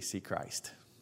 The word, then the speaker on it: Sechrist: That's spelled S-E-C-H-R-I-S-T, see Christ.